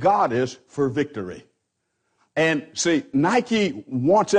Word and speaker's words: Nike: It's a part of the word nike. We spell that N-I-K-E, and we pronounce it Nike.